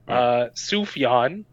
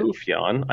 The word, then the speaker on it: Sufjan: Sufjan apparently is how you pronounce it. – Sufjan.